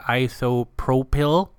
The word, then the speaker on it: isopropyl: It is spelt I-S-O-P-R-O-P-Y-L, and I believe you pronounce that isopropyl, and I can never remember. It's isopropyl. It's in a clear bottle, usually a square bottle.